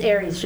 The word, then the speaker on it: Aries: So anyhow, we got Piper's Scratch Pizza, and we got Aries, and I say that right? Is that like the sign? – It's Aries, just like the sign.